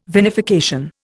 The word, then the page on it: vinification: Indian